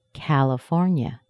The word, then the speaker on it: California: Can you hear where the primary stress is? That's at four. California. There's a secondary stress in this word. Ca, C-A in the beginning of the word. Ca, A is the vowel sound. It's a pure A sound. Ca-la-for-nya. California. So we have a secondary stress in California. That's C-A.